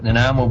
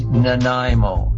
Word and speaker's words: Nanaimo: Listen carefully, everyone. It is Nanaimo. Nanaimo.